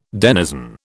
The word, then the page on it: denizen: British English